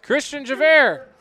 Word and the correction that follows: Christian Javier: I'm sorry, that's Christian Javier. – Christian Javier. – My bad on the pronunciation there, but that's a touchdown for the junior.